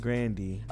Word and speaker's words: Grande: She says it's pronounced Grande. – Oh, it is? – Yeah, it's pronounced Grande, but everybody started saying Grande.